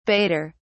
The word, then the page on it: baiter: British English